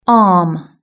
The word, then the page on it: arm: british-english